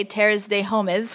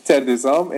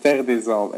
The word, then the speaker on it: Terre des Hommes: which I can't say Terres de Homes because I don't speak Italian or French. You know, I can't do it. How do you say it? – Terre des Hommes in Italian and Terre des Hommes in French.